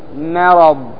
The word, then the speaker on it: مرض: الكلمة الثانية مرض مرض، لاحظ الميم هنا أيضا مرققة وليها حرف مفخم وهو الراء لأنه مفتوح تعتني هنا بالميم فتنطقها مرققة فتقول مرض ما مرض.